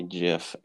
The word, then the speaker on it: GIF: How do you pronounce G-I-F? – Oh no, it's GIF.